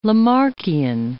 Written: Lamarckian

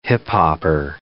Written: hip-hopper

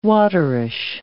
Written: waterish

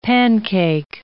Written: pancake

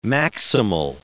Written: maximal

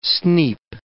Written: sneap

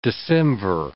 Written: decemvir

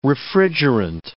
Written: refrigerant

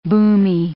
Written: boomy

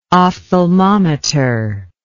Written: ophthalmometer

